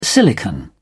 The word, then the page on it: silicon: British English